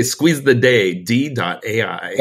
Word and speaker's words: Squeeze the Day: Am I saying that right? Squeeze the D AI? – We always say Squeeze the Day, D.AI.